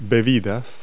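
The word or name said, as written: beverage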